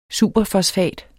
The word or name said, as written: superfosfat